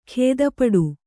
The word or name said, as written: ಗೊತ್ತುಪಾಡು